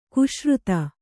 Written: ಕ್ಷೀರೋದ

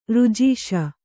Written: ಋಜೀಷ

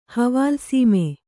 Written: ಹವಾಲ್‍ಸೀಮೆ

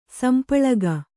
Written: ಸಂಪಳಗ

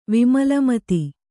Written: ವಿಮಲಮತಿ

